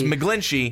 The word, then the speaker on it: McGlinchey: It's McGlinchy because that's how it's spelled.